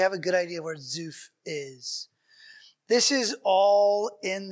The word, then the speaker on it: Zuph: If we go back to the geography, he was in Zuphar, or how do you pronounce it, Z-U-P-H, is where Samuel was. – Zuph. In Ramah.